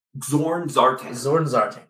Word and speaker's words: X'orn X'artan: Zorn Zartan, yes.